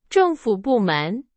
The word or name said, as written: 政府